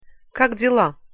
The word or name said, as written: kak dila